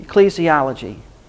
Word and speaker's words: ecclesiology: How do you pronounce that? – Ecclesiology. Ecclesiology. It's ecclesiology, the doctrine of the church.